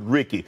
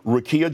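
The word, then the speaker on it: Rickea: But last night on my podcast, Nightcap with Ocho and I mispronounced Rekia Jackson. I said Ricky. So I want to apologize to her personally. Rekia Jackson, congratulations to you being selected for number 4 overall to the L.A.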